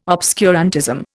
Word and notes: obscurantism: Indian

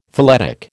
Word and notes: phyletic: American English

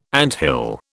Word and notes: anthill: British English

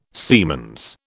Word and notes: siemens: British English

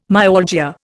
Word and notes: myalgia: Indian

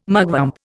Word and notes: mugwump: Indian

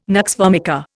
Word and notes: nux vomica: Indian

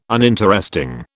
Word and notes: uninteresting: British English

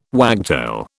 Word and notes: wagtail: British English

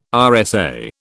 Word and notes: RSA: British English

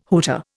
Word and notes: hooter: Indian

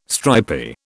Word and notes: stripy: British English

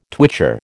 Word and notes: twitcher: American English

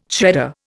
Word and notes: shredder: Indian

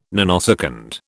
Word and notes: nanosecond: British English

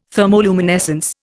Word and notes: thermoluminescence: Indian

